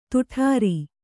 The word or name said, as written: ದಡಾರ